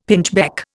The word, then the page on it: pinchbeck: Indian